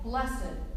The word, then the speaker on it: blessed: And so when we read the word blessed, as it is used in the Beatitudes, we read the word and pronounce it blessed.